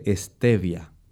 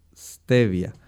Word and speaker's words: stevia: Pero si usted la consigue ya en forma granulada, como la sacarosa, como el azúcar de mesa, esa planta se llama así, tal como usted la pronunció, esa es su pronunciación en inglés. En español la gente le dice stevia, pero comercialmente la consigue por su nombre en inglés. Se escribe S-T de Tito, E-V-I-A, stevia.